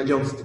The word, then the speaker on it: Yoast: plugin que se llama Just se llama Yoast, creo que se pronuncia. Y-O-A-S-T.